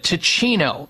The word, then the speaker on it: Teeccino: Ticino. It's spelled T-E-E-C-C-I-N-O.